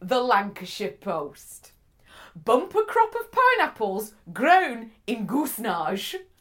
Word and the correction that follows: Goosnargh: I would call that goosenar. – Goosenar. Goosenar, fine. – Spell it. Spell it for me. – G-O-O-S-N-A-R-G-H. Goosenar. Goosenar? – Goosenar, I would say. Or goosenar it could be.